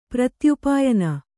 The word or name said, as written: ಪ್ರತ್ಯುಪಾಯನ